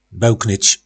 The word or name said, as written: belknyc